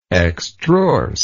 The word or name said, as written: extrorsely